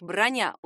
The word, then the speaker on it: броня: Именительный падеж — броня. Ударение на «я».